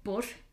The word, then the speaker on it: Badhbh: There are three mainly known ones, which, again, with this pronunciation, man, there is Bev, or Bosh, I think is how they pronounce it. It literally is spelled B-A-D-H-B-H, and the pronunciation is nothing like that whatsoever.